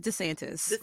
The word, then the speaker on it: DeSantis: for the record, because for the record, his name is DeSantis. – And you should say it properly DeSantis.